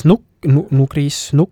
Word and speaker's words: nugris: Või siis nugris. See hääldub nugris, nukri ja nugrist.